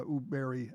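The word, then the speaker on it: Högberg: It's pronounced, it's spelled H-O-G-B-E-R-G, but it's pronounced Uberi.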